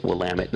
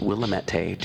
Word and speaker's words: Willamette: So then across the Willamette, we have, and that is Willamette, not Willamette. Do not pronounce it Willamette, or people will roll their eyes at you.